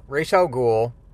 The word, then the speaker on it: Ra's al Ghul: It is these images of Ra's al Ghul, and yes, I do say it like the animated series pronounced it, so I don't care how anybody else does.